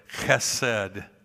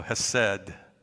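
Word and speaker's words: chesed (חֶסֶד): And so the word there is, it's like K-H-E-S-E-D. And it's kind of pronounced like a chesed, but I'm skipping the K, and I'm going to go chesed from here on out because I can't do that K thing.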